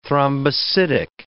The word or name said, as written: thrombocytic